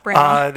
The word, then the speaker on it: Branagh: Branagh.